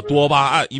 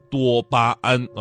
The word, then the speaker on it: 多巴胺: 啊，多巴胺，因为很多人呢都习惯性把它读成多巴胺啊，呃，你们是不是觉得，哎呀，这也没什么，我早就知道了哈？